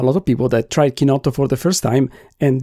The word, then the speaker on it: chinotto: What I would like to talk about is the drink chinotto, pronounced in Italian, chinotto. You would say, I suppose, with English accent.